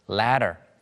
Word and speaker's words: latter: 먼저 즉흥적인 spontaneous 그리고 후자, 전자후자를 가리킬 때 후자의라고 하는 latter 마치 이제 사다리하고 발음이 굉장히 비슷해요.